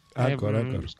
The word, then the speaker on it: Anchor: Anchor es un Anchor, ¿vale? – Anchor. – Como se escribe, digamos, en castellano.